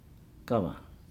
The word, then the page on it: gava: Nukuoro